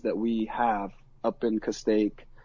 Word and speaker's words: Castaic: He lived in a city called Castaic, which is about 70 miles. – How do you spell that? – C-A-S-T-A-I-C, Castaic.